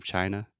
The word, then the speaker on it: China: So the pronunciation of China is just chai, just like vai with a ch sound in the beginning, and then the schwa, China. And technically, this is not a mispronunciation, but he just says it really weird.